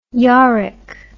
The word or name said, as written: yorick